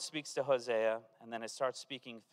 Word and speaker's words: Hosea: You pronounce it, the English pronunciation is the Hebrew pronunciation, so we don't have to work on that. You say Hosea, and you're saying it the way they say it in the Bible, and it means salvation.